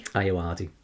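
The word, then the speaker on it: Ayoade: Is it Richard Iodi? – Iowardi. – Iowardi.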